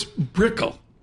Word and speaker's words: Brickell: So it's Brickell. – Brickell. – Brickell. – Brickell, yes. – Not Brickell. – Exactly, not Brickell.